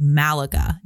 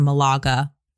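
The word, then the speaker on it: Málaga: We're in a little teeny tiny place called Malaga, named after, or Malaga is how they pronounce it in Spain.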